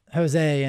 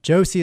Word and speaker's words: Joosse: But I've got this super weird last name, J-O-O-S-S-E, and it's mispronounced Jose and Juicy and Jose. And I mean, I've heard crazy pronunciations, but I certainly don't mind people mispronouncing it. I have embraced it. We made a little commercial about people mispronouncing my name, and it's on the website. But Josie is the right way to say it, and I wanted the family in there as an anchor.